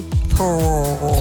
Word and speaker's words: Theroux: Louis Theroux.